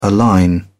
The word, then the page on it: align: British English